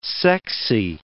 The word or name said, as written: sexy